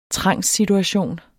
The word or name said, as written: trangssituation